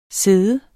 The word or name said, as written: sid